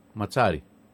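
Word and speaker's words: Mazzarri: 3-5-2 μπορεί να παίξει και ο Ηρακλής. – 3-5-2 θα παίξει με τον καινούργιο προπονητή, τον Βάλτερ Ματσάρη ή Ματζάρη τελικά. Μας φάγατε. Ματσάρη. – Όπως ένα μιλάει Ιταλικά, θα έχει και τη σωστή προφορά. – Με Ματζάρη. Με δύο ζήτα είναι ματσά, ζήτα.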